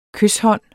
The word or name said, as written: kyshånd